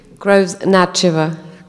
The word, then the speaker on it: Groves Natcheva: Groves Natcheva. Am I pronouncing it right or wrong? – Very close. – What should it be? – Groves Natcheva.